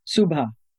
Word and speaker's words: Subha: I have to tell you that when you first come to this country, my name is a two-syllable name, Subha, okay? They would shorten it to Sue.